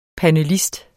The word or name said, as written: panelist